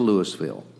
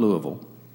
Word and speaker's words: Louisville: And it is pronounced Louisville, not Louisville, because I called the city of Louisville government this week to make sure.